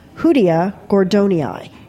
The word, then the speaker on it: Hoodia gordonii: Hoodia gordonii. Did I pronounce that right? – Yeah. – Okay. – Hoodia.